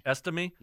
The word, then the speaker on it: Estime: Estime.